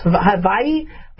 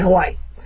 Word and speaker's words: Hawaii: Not so exciting is there's a resident in Hawaii, I know I should be pronouncing it correctly, but it's, you know, the W is pronounced a V, so it's Hawaii, but I still don't sound like I'm saying it right, so I might as well just say Hawaii.